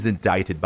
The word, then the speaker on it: indict: 当然有个大前提，if he's indicted by a Manhattan grand jury，如果他被曼哈顿一个大陪审团啊正式的提起诉讼，这个 indict，注意它的读音是一个不规则读音，不是 indict 或者 indict，是 indict。